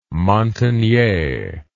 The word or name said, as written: Montagnais